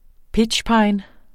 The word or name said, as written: pitchpine